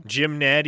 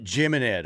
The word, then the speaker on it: Jim Ned: Jim Ned, yeah. – And I always thought they pronounced it Jim and Ed High School.